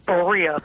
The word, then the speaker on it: Berea: I'm going to say Berea, Ohio. Did I pronounce that correctly? – It's Berea. B is Berea.